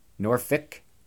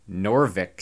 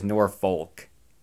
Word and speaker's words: Norfolk: Which, by the way, if you're from there, it really is Norfolk or Norvik. Some of the old timers say Norvik. Nobody says Norfolk. That's how it's actually spelled.